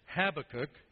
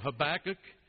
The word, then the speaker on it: Habakkuk: Some say Habakkuk. Others of us, and I'm part of that, so I like to think it's the right way to pronounce it, is Habakkuk. But I really think that the Brazilians have the best way of saying it. They say have a cookie, which sounds like have a cookie.